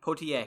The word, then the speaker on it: Poitier: Poitier.